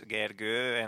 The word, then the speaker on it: Gergő: It's Gergő, and most of the people I talk to can't really pronounce it.